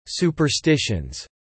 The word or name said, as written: superstitions